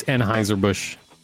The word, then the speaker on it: Anheuser-Busch: It's Anheuser-Busch. I know it's spelled totally different, but that's what it is.